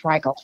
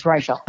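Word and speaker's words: Reichl: Well, first of all, I'm glad you know how to pronounce her name because I've always called her Ruth Reichel. – I know. – So it's Reichel. – I know. Reichel. It's Reichel. Exactly.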